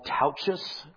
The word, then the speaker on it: Tautges: His name is Paul Tauchus, rhymes with couches, but it's spelled T-A-U-T-G-E-S.